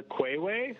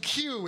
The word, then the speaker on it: queue: The Quayway? – The queue. It's pronounced Q.